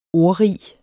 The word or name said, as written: ordrig